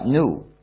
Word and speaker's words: gnu: Se usa para muchos juegos de palabras, porque según el diccionario se pronuncia new, como nuevo.